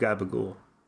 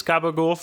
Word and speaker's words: gabagool: And to make Gabagool Tony, he's turned himself into a piece of Gabagool. And people thought. – And what is Gabagool for those of us not from the East Coast? – It's the regional pronunciation for capicola, a type of Italian cured meat, not dissimilar from cancer.